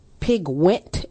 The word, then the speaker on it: Piquent: What is the pronunciation of that water? It's Piguent, P-I-Q-U-E-N-T.